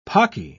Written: pocky

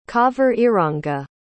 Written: cauveriranga

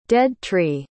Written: dead-tree

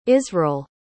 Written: isreal

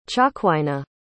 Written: Cha'kwaina

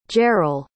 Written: jerrel

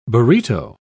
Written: burrito

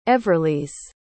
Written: everlyse

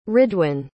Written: ridwan

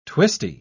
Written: twisty

